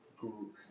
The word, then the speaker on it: grew: Grew. – Y suena como grew. – Grew. – Grew. – Grew. – Toma un segundo para sentir cómo se pronuncia GRU.